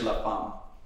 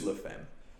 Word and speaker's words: La Femme: It's pronounced La Femme and in French, La Femme. – Yeah. – So yeah, English is La Femme.